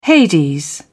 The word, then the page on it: hades: British English